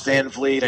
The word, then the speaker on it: Van Vliet: I'm still, you know, at that point where I'm like, no, it's Van Vliet. I know it's spelled funny.